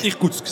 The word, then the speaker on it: Irkoutsk: Irkoutsk, c'est juste. – Irkoutsk.